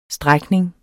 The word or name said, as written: strækning